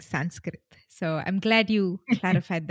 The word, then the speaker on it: Sanskrit: But the actual pronunciation is Sanskrit.